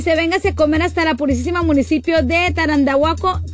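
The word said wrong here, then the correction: Tarandacuao: Tarandaca, siempre se me olvida, siempre, ya me mandaste un audio, please, de cómo se pronuncia, Tarandacuau, sí, Tarandacuau, Guanajuato.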